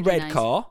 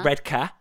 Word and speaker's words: Redcar: It's in Redcar, or Redcar, as the locals say, in the northeast.